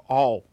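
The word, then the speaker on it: Aul: Gabe All. I think that's how you say it, yeah. – Okay, as in all. – As in all, okay.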